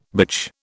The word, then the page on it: bitch: British English